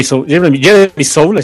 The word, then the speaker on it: Soule: La primera sinfonía de Jeremy Soule,